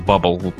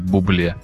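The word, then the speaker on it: Bublé: Не путайте с Бабл. Я, кстати, его всегда называл Бабл, потому что он пишется так же. Оказывается, надо произносить его фамилию как Бубле.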